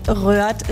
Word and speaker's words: Rheurdt: Unsere Schreibweise des Ortes, weil Röhrt ist wirklich ganz speziell. Es wird geschrieben R-H-E-U-R-D-T. Da ist das Dehnungs-H drin, das stumme D, das E-U, was wie Ö ausgesprochen wird.